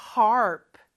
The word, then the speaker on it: harp: 하프는 harp, harp. R 발음 잘 넣어주시고요.